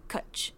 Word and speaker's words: cwtch: It is a Welsh word and it is spelled C-W-T-C-H. And according to Google, it's pronounced Kutch. But it has just no vowels.